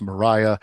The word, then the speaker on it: Maria: We open the book with first person Mariah Wyeth, and we are told in her narration that her name is pronounced Mariah, even though it is clearly spelled Maria.